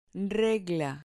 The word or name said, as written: regla